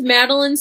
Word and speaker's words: Madelein: Well, my name is Madeline Smith. It's spelled a little bit weird. It's M-A-D-E-L-E-I-N.